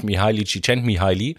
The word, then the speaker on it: Mihaly Csikszentmihalyi: Ne, der Psychologe, der sich damit am meisten beschäftigt hat, ist natürlich Mihaly Csikszentmihalyi.